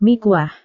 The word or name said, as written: mie kuah